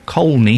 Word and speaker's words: Colney: There is an L in it. You pronounce the L. Colney Hatch Lane.